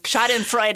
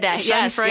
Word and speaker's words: schadenfreude: you use the word schadenfreude in your book. – Schadenfreude. – Schadenfreude. – Yes. – Okay, that's how you say it.